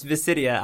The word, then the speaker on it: Visidia: So if you want to talk about it, I can just do, like, it's Visidia. I'll just give you, like, the pronunciation guide.